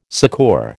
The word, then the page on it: succour: American English